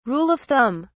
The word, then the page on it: Rule of thumb: British English